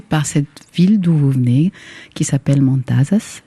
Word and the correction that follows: Matanzas: Je prononce très mal ou juste? – Matanzas,